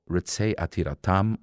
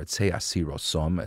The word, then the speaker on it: Ritzei Atiratam: It's called Ritzei Atiratam, or Ritzei Asirosom, as he pronounces it in the Eastern European way.